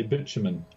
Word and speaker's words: bitumen: How do you pronounce that? – In English, we say bitumen.